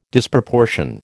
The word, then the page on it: Disproportion: American English